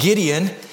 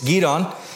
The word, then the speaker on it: Gideon: Now, some of you guys who have been Christians for a long time it's not Gideon. That's what I was raised to, but as I'm working really hard on these correct pronunciations, it's Gideon.